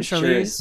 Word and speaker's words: Charlize: Charlize. That's how you say it, Charlize? – Sure.